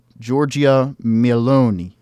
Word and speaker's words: Giorgia Meloni: So, yes, Giorgia Meloni, as they say.